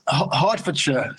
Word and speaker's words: Hertfordshire: It's Hertfordshire.